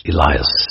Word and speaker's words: Elias: Let us now go back to the topic of Prophet Elijah, whose name is pronounced Elias in both the Arabic and the Roman languages.